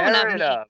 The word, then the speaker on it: Merida: Mieda? – Nope. No, Merida. Not Mieda.